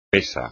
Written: pesa

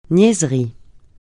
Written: niaiserie

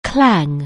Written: clang

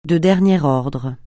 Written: de dernier ordre

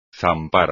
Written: zampar

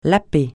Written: laper

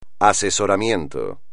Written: asesoramiento

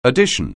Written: addition

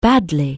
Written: badly

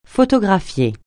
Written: photographier

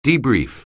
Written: debrief

